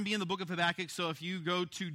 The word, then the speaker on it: Habakkuk: And it has that double K on the end, so it's Habakkuk, and you got to kind of get a sound in order to really pronounce it well.